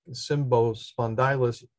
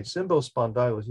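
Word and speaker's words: Cymbospondylus: So Symbo, which actually I think I have it correct in the pronunciation, Symbo, C-Y-M-B-O, Spondylus, S-P-O, and D-Y-L-U-S, and then the second part of the species genera name is youngum.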